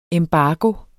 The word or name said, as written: embargo